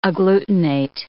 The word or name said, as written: agglutinate